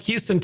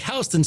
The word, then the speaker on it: Houston: One is pronounced like Houston, Texas, and the other is pronounced like Houston Street.